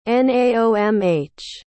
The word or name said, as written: naomh